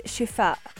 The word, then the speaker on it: Shifaa: The app is called Shifa, and it's spelled S-H-I-F-A-A. And it's found on the Google Play Store as well as the Apple App Store.